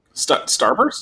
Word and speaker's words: Starburst: I guess Strawburst? – Starburst? – Starburst, sorry, yes. I was pronouncing Strawburst, but oh, okay.